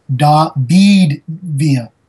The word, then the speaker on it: David Villa: And before I forget, on the other part for Dillis Donuts, I will say David Villa. So you pointed out that I was mispronouncing it.